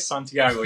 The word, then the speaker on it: Santiago: You said it right, Santiago, just like that.